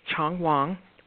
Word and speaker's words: Qiang Huang: Well, the next painter we're going to talk about is Chong Wang, and his name is not spelled as it sounds. It's spelled Q-I-A-N-G-H-U-A-N-G.